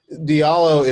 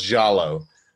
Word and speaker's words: Diallo: Diallo is how I pronounce it, and it's sort of the anglicized version of the name. It could be pronounced Jallo, because over there they have a place that looks very much like my name called the Futa Jalon Mountains.